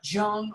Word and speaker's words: Jung: J-U-N-G is pronounced Jung, not Jung or Jung, but Jung, Carl Jung.